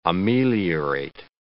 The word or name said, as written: ameliorate